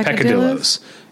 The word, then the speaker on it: peccadillos: Picadillos. – Oh, that's a good one. Yeah. How do you say it's, is it peccadillo? Yeah, peccadillos. It's Piccadilly Circus. – Piccadilly Circus. Peccadillos. – Yeah.